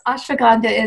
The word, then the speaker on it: ashwagandha: Oh, yes, ashwagandha is spelled A-S-H-W-A-G-A-N-D-H-A, ashwagandha.